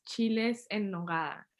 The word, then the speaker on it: chiles en nogada: I said the name wrong. It's chiles en nogada.